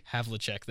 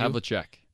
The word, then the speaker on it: Havlicek: Havlicek. – Thank you. – Havlicek.